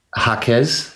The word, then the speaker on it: Jaquez: Which is spelled J-A-Q-U-E-Z. And I could have butchered and said Jaquez, but it's as my wife told me coming in too. She's Puerto Rican. She's like, it's a Jaquez.